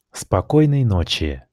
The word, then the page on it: спокойной ночи: Russian